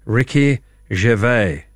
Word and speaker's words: Ricky Gervais: Please pronounce it for me. Ricky Gervais.